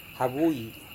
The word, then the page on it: havui: Serewen